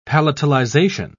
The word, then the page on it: palatalization: British English